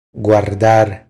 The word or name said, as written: Guardar